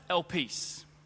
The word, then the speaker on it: elpis: It's pronounced elpis. Elpis. It's spelled E-L-I-P-I-S.